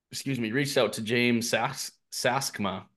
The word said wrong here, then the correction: Stixma: however Stixma. – You pronounce it. Yeah, Stixma. And he sent me his list of questions that he had for when he acquired.